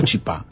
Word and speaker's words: chipá: ¿Es chipa o chipá? – Sí, no sé, ¿cómo se dice? – Se puede decir de las dos maneras. – Sí, creo que la correcta es chipá, pero nosotros le decimos chipa.